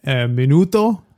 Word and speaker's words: minuto: Minuto.